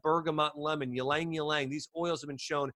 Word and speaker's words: ylang ylang: Another, actually probably my favorite oil for uplifting the mood, it's called ylang ylang. That's spelled Y-L-A-N-G, Y-L-A-N-G. Okay, it's two, ylang ylang.